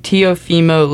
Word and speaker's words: Teofimo: His name's Tiofimo Teofimo Lopez. Okay, I'm pretty sure you butchered that name, but that yeah, th- that's exactly what I thought.